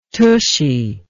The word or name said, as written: tushy